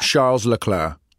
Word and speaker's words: Charles Leclerc: Charles Leclerc. How did I say his name? Beautifully? – Fine enough, yes.